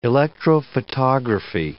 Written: electrophotography

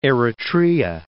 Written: Eritrea

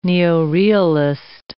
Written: neorealist

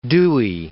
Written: Dewey

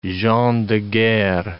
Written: gens de guerre

